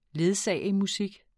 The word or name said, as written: ledsagemusik